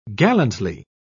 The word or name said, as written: gallantly